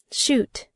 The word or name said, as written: shoot